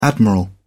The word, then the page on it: admiral: British English